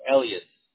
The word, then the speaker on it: Elias: And am I saying your name right? It's Alicio? – No, Elias. – Elias. And that's with the A at the end, right? – Yes, A-S. A-S, correct.